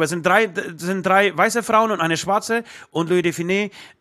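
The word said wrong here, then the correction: de Funès: oder de Finesse, glaube ich. – De Finesse wird ausgesprochen.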